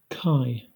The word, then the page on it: chi: English